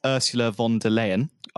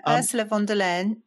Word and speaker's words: Ursula von der Leyen: So, you know, the Commission President, Ursula von der Leyen, I probably said that wrong.